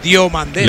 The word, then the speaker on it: Diomandé: Y Diomandé. El tercero. Diomandé, si he dicho Diomandé, no me digáis que digo Diomandé, es Diomandé.